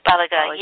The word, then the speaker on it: Baliga: but Sujatha Baliga, is that how you pronounce her name? – Baliga, yeah, director of the Restorative Justice Project at Impact Justice.